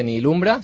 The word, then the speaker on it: Nihilumbra: Realmente, si queréis, podéis saber un poquito más de Nihilumbra, se escribe con H, como nihilismo.